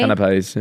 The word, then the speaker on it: canapés: So the first top floor was like canapé. – All prim and proper. – Is that proper. – How you say it? Canapé? – Canapés, yeah.